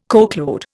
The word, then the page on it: coachload: Indian